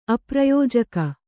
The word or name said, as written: ಅಪ್ರಯೋಜಕ